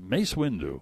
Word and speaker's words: Mace Windu: And Mace Windu, am I saying that right? – That's right. – Mace Windu.